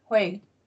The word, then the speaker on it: Hwei: Is it Hwei? I want to say her name right. – Hwei, yeah. – It is Hwei.